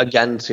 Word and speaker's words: Ghent: And I look forward to seeing you in Ghent? How is it pronounced? – True. Yeah, Ghent, it's the same thing.